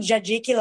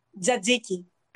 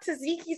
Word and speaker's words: tzatziki: I they usually put tzatziki, like the sauce. – How do you say it? – Tzatziki. – I've been saying it wrong. It's not tzatziki.